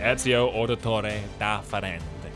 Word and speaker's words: Ezio Auditore da Firenze: Ezio Auditore da Firenze.